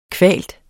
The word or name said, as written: kvaltes